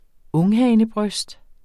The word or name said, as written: unghanebryst